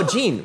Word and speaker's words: Jean: Who's Wyclef Jean? – Wyclef John? – John? Shakira. – John? – No, Gene. Wyclef Jean, right? I'm not saying that. – You're saying it right.